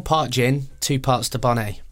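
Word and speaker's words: Dubonnet: William is pouring a gin and a bonnet now. That sounds like something from a sound effect, but he is actually recording it. So it's basically, the bonnet is spelt the bonnet, and it's a French liqueur.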